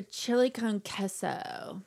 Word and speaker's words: chili con queso: This is a chili con queso. So, and that's how you say it when you look at the Lay's bag.